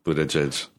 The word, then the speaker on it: Buttigieg: It's Mayor Pete Buttigieg. Judge. – Ooh. – How do you pronounce his name? Buttigieg? – Yeah, Buttigieg.